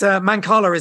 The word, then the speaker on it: Mancala: Yes, it's Mancala is the right way to pronounce it.